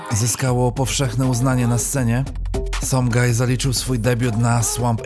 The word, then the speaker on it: Sumgii: Somgaj, bo tak naprawdę wymawia się jego pseudonim, to pochodzący z UK producent o niepowtarzalnym stylu.